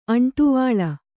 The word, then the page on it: ಅಂಟುವಲೆ: aṇṭuvale